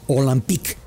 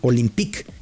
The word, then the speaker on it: Olympique: Por eso el nombre, Olympique, o por pronunciarlo muy galamente, espero que luego no me corrijan, Olympique.